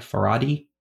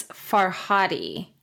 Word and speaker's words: Farhadi: I think I'm pronouncing, I'm going to say Fardi, F-A-R-D-I, Faradi. – I believe it's Farhadi.